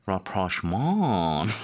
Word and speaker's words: rapprochement: There are similar rapprochement.